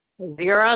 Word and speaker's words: Vera: Vera, like in Vicky, E-R-A. – E-R-A.